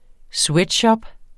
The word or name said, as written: sweatshop